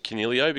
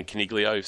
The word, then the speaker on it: Canelio: For those who haven't seen it, the video that was put out by the Giants media department on the pronunciation of Canelio, because the way it's pronounced in Italian, the G is silent, and he's been getting Caniglio since coming into the competition.